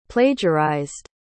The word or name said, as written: plagiarized